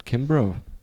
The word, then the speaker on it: Kimbrough: Kimbrough.